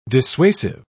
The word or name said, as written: dissuasive